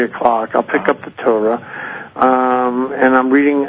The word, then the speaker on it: Torah: On when you read the Tarot, do you... – The Torah? Yes. – Oh, Torah. I'm sorry. I mispronounced it.